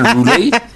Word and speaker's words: roulette: Or roulet. I don't know how you pronounce it. Is it roulet? – It's actually roulet.